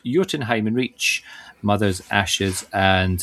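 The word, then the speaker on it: Jötunheim: And following the destruction of the bridge to Jotunheim during the fight with Baldur, Kratos and Atreus, we need to find effectively an alternative route into the realm of the giants. – Lovely, lovely pronunciation of Jotunheim there.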